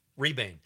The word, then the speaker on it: Rebane: But yeah, mostly about the director, Bill Redbane, I think. Okay. That's how you say it. Rebane.